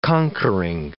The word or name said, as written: conquering